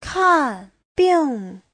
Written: kan4bing4

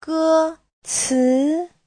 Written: ge1ci2

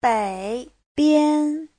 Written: bei3bian1

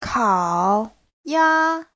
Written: kao3ya1

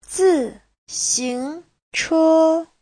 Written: zi4xing2che1

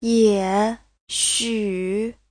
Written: ye3xu3

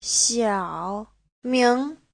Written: xiao3ming2

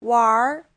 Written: wanr2

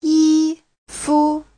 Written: yi1fu5

